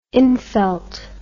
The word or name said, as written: infelt